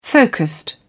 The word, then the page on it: focused: British English